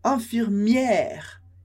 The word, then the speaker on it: infirmière: Então, infirmier, enfermeiro, infirmière, enfermeira. Então, infirmier, enfermeiro e infirmière, enfermeira. Com esse som bem aberto do E.